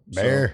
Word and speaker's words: Bexar: Bear County is actually spelled B E X A R, but it's pronounced Bear. And I know that when we did the Joe Ball episode, I'm pretty sure I kept saying Bexar County. So for you Texans out there, I'm sorry. I didn't realize it but I know that I was saying it that way. But it is spelled that way. Bexar. But it's bear.